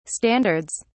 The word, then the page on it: standards: British English